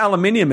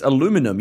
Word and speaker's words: aluminium: Well, the weird thing about aluminium is it's not even just pronounced differently. It's aluminum. You take a whole syllable out.